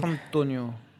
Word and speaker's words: Mastrantonio: Mastrantonio.